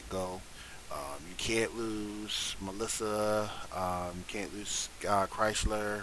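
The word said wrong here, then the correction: Chyler: if I said her name right. – Skylar. – Skylar. Chrysler.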